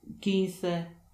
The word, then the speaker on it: quince: Q wymawiane jest jak polskie K, jednak wchodzi tylko i wyłącznie w połączenie QUI, QUE, wtedy wymawiane jest jak ke, ki. Na przykład quince pequeño.